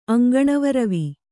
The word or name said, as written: ಅಂಗಣವರವಿ